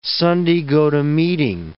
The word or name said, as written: Sunday-go-to-meeting